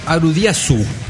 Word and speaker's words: Aldious: Esta banda, Aldios, se pronuncia Arudiasu.